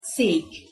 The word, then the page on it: szek: Hungarian